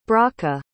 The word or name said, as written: brachah